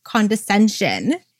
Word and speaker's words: condescension: condensation. Did I say that correctly? Condescension. There we go.